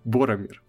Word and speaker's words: Боромир: Барами... Боромир. – Боромир. Да, да, Боромир, Кто? да. – Я правильно произношу его имя? Ну, Боромир. Вот.